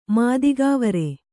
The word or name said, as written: ಮಾದಿಗಾವರೆ